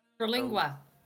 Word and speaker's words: Terlingua: Is Terlingua? – Terlingua.